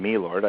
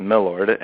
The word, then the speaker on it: milord: Well, my Lord or my Lord, it's not, I'm not saying me Lord, I don't think I pronounce it that way. It's my Lord, M-I-L-O-R-D, which is a shortened version of my Lord, is the proper form of address for a bishop.